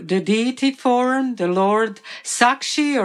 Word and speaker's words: Sakshi: They are also pastimes of the Lord, connected to Sakshi Gopal, or in Bengal it's told Sakhi Gopal, Sakhi or Sakshi Gopal. In Bengali language, that sh sound is not pronounced. So instead of Sakshi in Sanskrit, in Hindi, it's Sakhi.